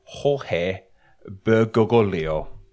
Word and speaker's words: Jorge Bergoglio: I'll spell it out as well. The name is Jorge Bergoglio. – That's okay, spell. – J-O-R-G-E-B-E-R-G-O-G-L-I-O. Jorge Bergoglio.